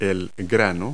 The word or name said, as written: kernel